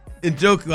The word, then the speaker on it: Njoku: How do you say David's last name again? Njoku.